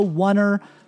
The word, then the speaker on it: Oner: It's called The Oneer Podcast. That's spelled The O-N-E-R, The Oneer.